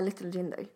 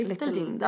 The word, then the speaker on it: Little Jinder: Little Jinder. Det uttalas på svenska. – Little Jinder.